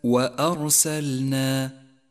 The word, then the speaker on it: وأرسلنا: اقرأ كلمة وأرسلنا وأرسلنا، وحاذر من تفخيم الهمزة، الهمزة حرف مرقق دائما، ولكن حاذر من تفخيمه هنا لأن الحرف الذي بعدها جاء مفخما وهو الراء، هذه الراء مفخمة لأنها جاءت ساكنة وقبلها مفتوح، ولذلك لونت بالأزرق الغامق أرسلنا.